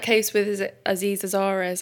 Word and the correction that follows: Aziz Ansari: I don't know how to say his name properly. – Aziz Ansari, yeah.